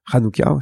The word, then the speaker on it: Hanouka: Hanouka, si on veut bien prononcer.